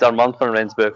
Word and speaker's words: Armand van Rensburg: Yeah, it's Armand van Rensburg from South Africa, yeah.